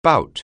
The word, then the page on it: bout: anglais